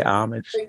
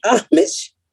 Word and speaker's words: homage: You don't got to dress up to pay homage. – Yeah. – Is it homage? – No, it's not homage. It's Miss Pat, listen to me very carefully. Here's how you say it. Homage.